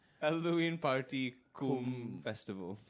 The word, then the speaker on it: cum: To be fair, also it's pronounced cum. – Yeah. – Like, oh, is it? – It's like summa, it's not, cum laude.